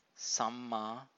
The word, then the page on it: samma: Pali